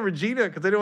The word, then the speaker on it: Regina: We were, well, the first we ever did was in Regina, Saskatchewan.